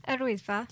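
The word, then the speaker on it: Yr Wyddfa: Arwyddfa.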